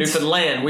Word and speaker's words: Newfoundland: Newfoundland. – We've learned you gotta pronounce the land. – Yeah, emphasize the land.